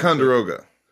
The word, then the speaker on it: Ticonderoga: I'm not going to pronounce Ticonderoga.